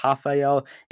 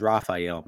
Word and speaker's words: Rafael: So it's spelled the same way, but one is Rafael and one is Rafael. Make sure you guys get that clear.